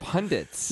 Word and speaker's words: pundits: You're adding an N? – I've always added that N my entire life. – That's not how you say it. – Pundits.